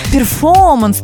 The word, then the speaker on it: перформанс: Ну, я думаю, Стинг бы одобрил такое произношение, но все-таки в русском языке слово «перформанс», оно и пишется с буквой «Р», с двумя буквами «Р», и проговаривается точно так же. То есть эту букву мы не теряем при произношении.